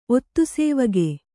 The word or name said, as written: ಒತ್ತುಸೇವಗೆ